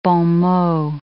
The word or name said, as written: bons mots